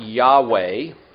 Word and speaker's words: Yahweh: And we pronounce it Yahweh. The Germans say Jehovah. I think Yahweh is probably a better rendering.